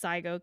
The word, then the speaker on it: Czigo: I only know that it's spelled C Z I G O, but I only know it's Zygo because I listened to the audiobook.